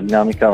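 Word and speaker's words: Dynamica: Ok, allora, per prima cosa puntualizzo solo una cosa sul nome, nel senso che sì, si pronuncia Dinamica, ma la prima è una Y, quindi D-Y-Namica,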